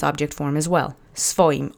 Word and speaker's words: swoim: Now, note that it says swoim, but in Polish, as sometimes happens, we try to simplify the syllables in our speech. So SW, sw would be pretty hard to pronounce, so we soften the W as sometimes happens, and we've seen this in other words before in prior lessons. So you don't say svoim, you say svoim. Accent is on the first syllable.